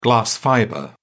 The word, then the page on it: glass fibre: British English